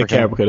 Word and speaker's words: Caprica: Caprica. That's how you pronounce it, right? – Caprica? Yeah, Caprica, that's correct.